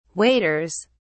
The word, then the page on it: waders: British English